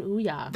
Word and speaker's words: Ouya: Why are you saying Ouya? – That's how you pronounce it.